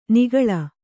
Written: ನಾಗುಳ

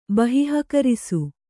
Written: ಬಹಿಃಕರಿಸು

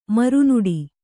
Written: ಮರುನುಡಿ

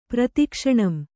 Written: ಪ್ರತಿಕ್ಷಣಂ

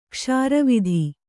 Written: ಕ್ಷಾರವಿಧಿ

